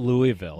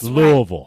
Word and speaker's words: Louisville: It's not Louisville. – That's Louisville. – That's right. You got to say it right, baby.